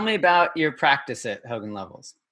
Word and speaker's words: Hogan Lovells: My guest today is Michael Turrell, who is a partner with Hogan Lovells in Los Angeles. Am I pronouncing that right? Hogan Lovells? – Hogan Lovells. Yeah. – Hogan Lovells. Okay.